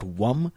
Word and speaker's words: Vum: So we have Lum, spelled L-U-M for the root chakra. Then we've got Vum, V-U-M for the sacral chakra.